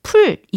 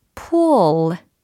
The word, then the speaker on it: pull: 발음은 풀 이게 아니에요. 예, 풀칠하다 할 때 풀 이게 아니라 pull, pull, pull. 뭔가 l, l 느껴지십니까? 이 탄성이 느껴지십니까?